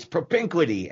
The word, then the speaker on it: propinquity: that I hope people will learn how to pronounce it correctly. It's propinquity, and it means nearness.